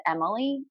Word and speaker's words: Emilie: Our handle is at Joe and Emily. And my name is spelled E-M-I-L-I-E. So it's J-O-E-A-N-D-E-M-I-L-I-E.